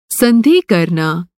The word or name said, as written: संधि